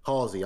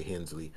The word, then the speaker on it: Halsey: Halsey, I always say Hensley.